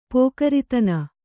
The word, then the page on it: ಪೋಕರಿತನ: Kannada